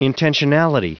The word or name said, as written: intentionality